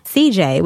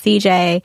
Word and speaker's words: Ceejai: On this particular episode, on the finale that we're talking about, CJ, which is spelled CEEJAI.